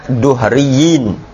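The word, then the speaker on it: duhriyin (الدهريين): Itu bacanya duhriyin. Tahu duhriyin? Nah, duhriyah. Bukan, enggak, duhriyin ini anu dia. Beda reinkarnasi itu namanya duri. Ada duhriyah, ada duriyah.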